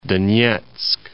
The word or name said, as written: Donetsk